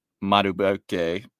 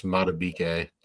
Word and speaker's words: Madubuike: And your defensive tackle, Nnamdi Madubuike. Terrible. I'm sorry. – It's just Madubuike. Don't let Tuck hear you mispronounce that.